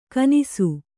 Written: ಘನಿಸು

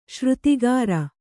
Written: ಶ್ರುತಿಗಾರ

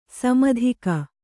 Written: ಸಮುದ್ಗ

